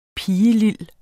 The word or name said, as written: pigelil